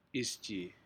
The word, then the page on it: isjii: Aneityum